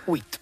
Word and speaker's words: huit: Huit.